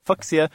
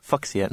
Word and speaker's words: Fuchsia: The correct fuchsia. because it was named for a Japanese botanist, Dr. Fuxi something or other. I can't think of his name, but the correct pronunciation of his name was Fuchsia. So therefore, you have to pronounce it Fuchsia, not Fuchsia.